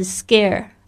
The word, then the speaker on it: scare: OK，第一个我们要记忆的单词就是 scare，特别注意一下这个 C 的发音，发格的声音。为什么呢？